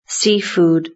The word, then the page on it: seafood: British English